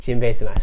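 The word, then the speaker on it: Jinbei-same (ジンベエザメ): Jinbei Zaman. – Okay, Simpe-same. – No! Jinbei-same.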